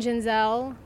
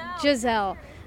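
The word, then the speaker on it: Gisele: How do you feel about Tom Brady and Giselle? – It's Giselle. – I always say her name wrong.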